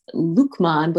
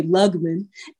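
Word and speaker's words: Luqman: Lukman is pronounced Lukman, but when I was a kid, everyone would think the Q in my name was a G. And so they'd always say Lugman.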